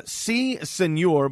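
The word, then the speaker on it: Sea Señor: C Senor, but the C is spelled S-E-A.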